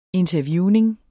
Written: interviewning

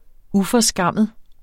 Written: UFORSKAMMEDE

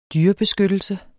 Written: dyrebeskyttelse

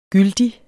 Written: gyldig

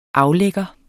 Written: aflægger

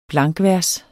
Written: blankvers

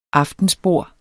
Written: AFTENSBORDETS

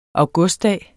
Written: augustdag

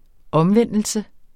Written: omvendelse

